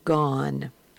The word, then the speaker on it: gone: And the word below that in the box is pronounced gone. Same O, consonant E, but it's pronounced gone.